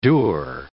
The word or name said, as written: dour